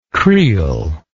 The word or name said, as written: creel